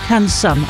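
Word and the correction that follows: Cancún: I'm not sure how to pronounce that. I didn't have time to look it up. – You mean Cancun, don't you? – Maybe so. Yeah, Cancun.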